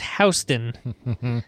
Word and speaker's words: Houston: Houston, it's pronounced Houston, Texas.